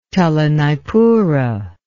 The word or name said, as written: telanaipura